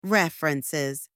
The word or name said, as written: references